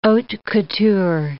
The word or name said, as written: haute couture